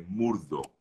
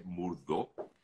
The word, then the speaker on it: Murdaugh: Em, para aquellos que quieran saber, que, están preguntándome muchísimo, se llama Los Asesinatos de Murdo. – O Murdo se escribe M-U-R-D-A-U-G-H, Murdo para aquellos que quieran buscarlo.